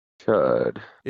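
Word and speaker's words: Chüd: it's the ritual of Chud, right? Or Chud? – Chud. – It's C-H-U-D, but the U has like the umlaut thing on it.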